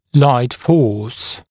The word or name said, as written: light force